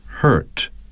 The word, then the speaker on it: hurt: 所以这一点呢，我想 会为大家读一下 bird 和 hurt 这两个单词，因为它能更好的展示这两个单词的在美式英语的读音是怎么样。 – Bird, bird, hurt, hurt. – Some speakers of British English do not sound the R in this word.